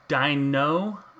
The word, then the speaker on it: Daigneau: Ken Dino, I want to say Dino, which is cool, but it's spelled D-A-I-G-N-E-A-U. So it's like a French dino.